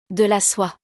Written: De la soie

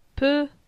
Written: Pe

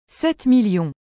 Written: 7000000

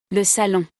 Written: Le salon

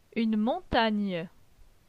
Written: Une montagne

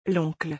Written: L'oncle

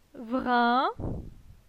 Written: Vrin

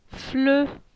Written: Fle

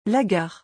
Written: la gare

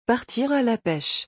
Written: partir à la pêche

